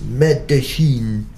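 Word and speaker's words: Medellín: Next to Medellin, which, like the locals, we learned to pronounce Medellin,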